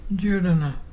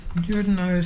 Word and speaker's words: Juruna: Judana. Judana is pelican. And to spell it is J-U-R-U-N-A. Can you say Judana?